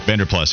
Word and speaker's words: Vanderplas: Cafaro, and then, like I said, Vanderplas, or I forget right. – how to pronounce his name. – Vanderplas, yeah.